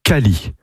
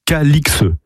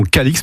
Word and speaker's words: Calix: J'ouvre ici une parenthèse, le nom était autrefois prononcé Cally, comme le montrent diverses formes anciennes du XIIIe au XVIIIe siècle, mais l'orthographe aberrante Calix a entraîné la prononciation actuelle Calix. Voilà pourquoi nous prononçons Calix depuis plus de 300 ans.